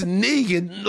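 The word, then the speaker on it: Knigin: Like, it's Negan, like from Walking Dead? – Yeah.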